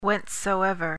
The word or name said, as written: whencesoever